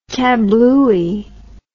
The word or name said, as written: kablooey